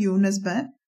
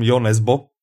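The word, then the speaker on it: Jo Nesbø: No, já, já nikdy nevím, to, to je, správně se to vyslovuje Jo Nesbe. – Jo Nesbe, ano. Jo Nesbe, uh, po našom Jo Nesbo. Ano, my to říkáme Jo Nesbo. Čiže normálně Jo Nesbo.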